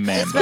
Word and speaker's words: Mambo: I don't know why I said Mumbo. It's definitely Mambo.